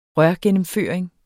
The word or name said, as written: rørgennemføring